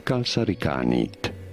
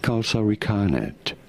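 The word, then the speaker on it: kalsarikännit: So how do you say it? Kalsarikanit. You do want to stop a little bit on the ka syllable. Kalsarikanit, or you could say in English as kalsarikanet, kalsarikanet, although in Finnish this is said something along the lines, and I think I'm pretty close here, kalsarikanit, kalsarikanet.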